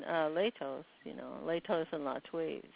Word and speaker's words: Latwii: JP, you were referring to one of the sources that is spelled L-A-T-W-I-I. And that is generally pronounced Latwi on this end.